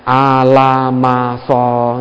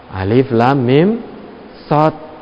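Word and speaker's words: Alif, Lam, Mim, Sod (المص): Diberikan Quran, bacanya Al-Masoh, itu tidak boleh. Apa itu Al-Masoh? Alif, Lam, Mim, Sod.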